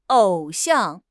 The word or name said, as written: 偶像